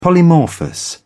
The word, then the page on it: polymorphous: British English